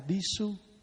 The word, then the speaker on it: Bhikṣu: Mà Nam Phạn thì đọc là Bhikṣu, mà Bắc Phạn đọc là Bhikkhu.